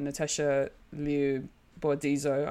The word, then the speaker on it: Natasha Liu Bordizzo: No, but just like even just the rest of the actors, you know, Rosario Dawson, Natasha Liu Bordizzo. I hope I'm saying that right. – Pretty close.